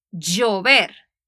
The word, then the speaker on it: llover: Pero en Latinoamérica, en la mayoría de los países, la L la pronunciamos como una Y. Doble L, en la mayoría de partes en Latinoamérica, la pronunciamos igual. So yo diría yo y llover, llorar. Mientras que en España y México dirían yo y después dirían llover y orar.